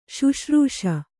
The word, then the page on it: ಶುಶ್ರೂಷೆ: śuśrūṣe